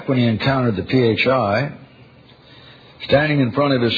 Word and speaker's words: phi: Of course, he pronounced it phi. Nice job, Stettner, Langdon said. Everyone, meet phi, P-H-I.